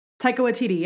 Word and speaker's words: Taika Waititi: Taika Waititi. – Taika Waititi, yeah.